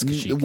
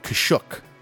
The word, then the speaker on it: Kashyyyk: It's pronounced a few different ways over the years. – Kashyyyk. Everybody knows it's Kashyyyk.